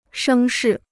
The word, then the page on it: 声势: shēng shì